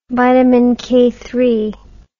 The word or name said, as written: vitaminize